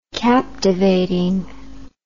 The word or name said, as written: captivating